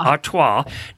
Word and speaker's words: Artois: Artois.